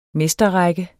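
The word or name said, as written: mesterrække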